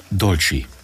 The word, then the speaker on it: dolci: Und äh, Dolce, Dolci, wie heißt es richtig? Dolci. Dolci, mhm. e wie i ausgesprochen? Ja, Dolci.